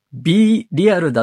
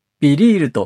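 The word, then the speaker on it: BeReal: 余談ですが、つい今日の朝、 B リアルだと思っていた、以前も紹介したことのある SNS がビリールと読むことを知って衝撃を受けました。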